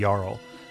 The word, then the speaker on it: Jarl: So what happened is, this is a tribe where anybody can challenge the Jarl, or the Jarl, or however you want to pronounce that, in order to become the new Jarl, and the old Jarl gets exiled.